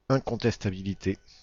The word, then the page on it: incontestabilité: /ɛ̃.kɔ̃.tɛs.ta.bi.li.te/; France